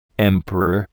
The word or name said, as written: emperor